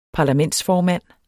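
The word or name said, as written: parlamentsformænd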